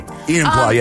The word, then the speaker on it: Enumclaw: Jesse in Numclaw. I think that's how you pronounce that. I hope and I pray. – Enumclaw, yes. – Enumclaw.